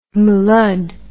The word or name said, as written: mlud